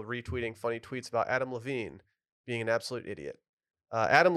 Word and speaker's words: Levine: It's pronounced Levine.